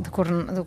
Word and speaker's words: Ducournau: Ducornau, sí, Ducornau. Jo tampoc ho sé pronunciar. Fantàstic. Júlia Ducornau, així més o menys sona, i ho trobareu a internet, sí, si ho busqueu.